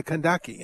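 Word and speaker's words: Kandake: And she was called the Candace, or the Kandaki, in the way it would have pronounced then.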